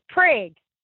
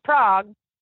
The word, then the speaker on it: Prague: So they actually even had, I mean, it's pronounced, it's supposed to be pronounced Prague, but these Oklahomians call it Prague, even though it was settled by people from Czechoslovakia, from actual Prague and they have a festival and everything.